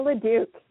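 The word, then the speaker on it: Leduc: You got it bang on, Tara LaDuc.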